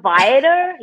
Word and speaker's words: Viator: I pronounce the R. So Viator. Yeah.